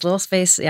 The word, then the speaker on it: LilSpace: Yes, LilSpace.